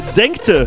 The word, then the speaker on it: Denkte: Und der Yannick schreibt, Sven aus Denken. Habe ich das falsch ausgesprochen? Nee, Denkte. Groß Denkte.